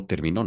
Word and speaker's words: terminó: No terminó, yo terminó, no, no, ella terminó. Accent on the O.